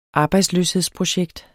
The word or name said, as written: arbejdsløshedsprojekt